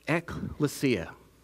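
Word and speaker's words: ekklesia (ἐκκλησία): In the Greek form, it is pronounced ekklesia, as you see on here on the screen.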